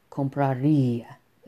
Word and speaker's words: compraría: Siempre con acento, así si escuchan la pronunciación, compraría. Stress en la i.